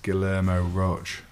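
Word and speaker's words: Guillermo Rauch: The Zeit HQ creator, I think it's pronounced Guillermo Roche. – I'd go with that, yeah.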